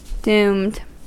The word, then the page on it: doomed: American English